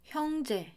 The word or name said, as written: 형제